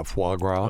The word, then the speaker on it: foie gras: I always mispronounce it. Frog wah. – Yeah, foie gras.